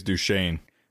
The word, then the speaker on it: Duchesne: But the reason I'm bringing them up is because there was an incident at Fort Duchesne. Is that how you pronounce that? – I think it's Duchesne. – Duchesne. – Yeah. – Oh, excuse me for trying to pronounce it phonetically.